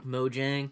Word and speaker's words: Mojang: No, actually, originally I was going to spend my Saturday night finishing this thing, but I forgot the story of Mohang. The Minecraft documentary airs on Xbox Live tonight. – Mohang or Mojang? – At PAX, that's how people were pronouncing it. – I never know. – Mojang.